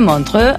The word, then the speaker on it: Montreux: casino de Montreux. A ver qué vas a decir, Juan Ramón, de mi pronunciación. Impecable.